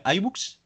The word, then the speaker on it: iBooks: Em, hoy me he dado cuenta, me, he enterado de que se pronuncia iBox, eh, iBooks, perdón.